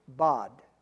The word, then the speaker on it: bad: Now, if we looked at it in English, you might pronounce it bad, B-A-D, bad. Now, you just have to think as though you were from Minnesota, because it would be pronounced bod, kind of like about, so it's bod. So it's not a bad thing, it's a bod thing.